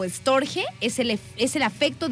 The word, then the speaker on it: storge: Después está el Storge o Storch, no sé, es Storge, se escribe con G, Storge.